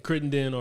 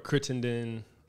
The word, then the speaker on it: Crittenden: That's a couple ways. Crittenden or Crittenden. – How your parents pronounce it? – Crittenden.